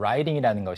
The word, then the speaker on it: riding: Riding이라는 것이 미국식 영어에서는 writing oh, yeah. – 하고 발음이 똑같잖아요.